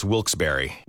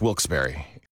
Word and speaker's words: Wilkes-Barre: Now, I had heard that it was supposed to be pronounced Wilkes-Barre. – Wilkes-Barre Scranton's 97.9X. The only way to pronounce Wilkes-Barre.